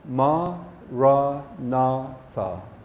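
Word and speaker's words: maranatha: So say it like this, four syllables, ma, ra, na, tha. Ma, ra, na, tha.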